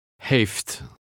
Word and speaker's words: heeft: Read number 14. Dutch F is pronounced the same as in English. – Heeft.